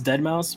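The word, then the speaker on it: deadmau5: I mean, I know it's pronounced dead mouse, but I still say dead mouse because like I can't bring myself to pronounce the five as an S.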